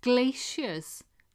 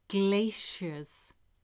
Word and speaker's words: glaciers: Some scientists have suggested that it was glaciers, not humans, that carried the blue stones to Salisbury Plain. Glaciers, or glaciers. I think there's American and British different pronunciation. The spelling is G-L-A-C-I-E-R-S.